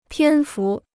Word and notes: 篇幅: piān fu

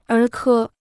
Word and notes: 儿科: ér kē

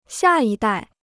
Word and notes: 下一代: xià yī dài

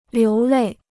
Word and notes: 流泪: liú lèi

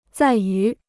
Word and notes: 在于: zài yú

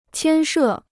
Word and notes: 牵涉: qiān shè

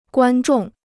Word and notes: 观众: guān zhòng

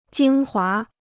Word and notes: 精华: jīng huá

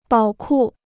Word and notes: 宝库: bǎo kù

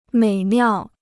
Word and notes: 美妙: měi miào

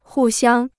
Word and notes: 互相: hù xiāng

